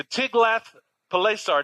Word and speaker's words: Tiglath-Pileser: Ahaz sent messengers to Tiglath-Pileser.